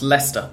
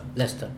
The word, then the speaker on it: Leicester: How is it pronounced? – In English, it's pronounced Leicester. – Leicester.